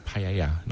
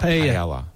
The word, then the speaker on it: paella: And it's paella, not paella. Paella. – Yeah, so double. – Yeah, that's how we pronounce double. – That. – Double L becomes a Y, basically.